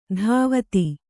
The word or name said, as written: ಧಾವತಿ